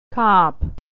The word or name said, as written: a cop